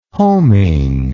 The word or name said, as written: homing